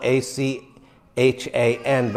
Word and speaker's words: Achan: Now it's spelled A C H A N, but it's pronounced Achan, like Aiken, South Carolina.